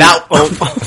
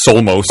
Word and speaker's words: Olmos: Edwards James Olmos. Not whatever name you said. You said something about Brazil. – I said, "Are you from Brazil?" – You said it all as one syllable, which I found to be pretty impressive. Right now. – I think you called him Edward James Solmos. Which may have thrown you off.